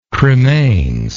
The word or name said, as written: cremains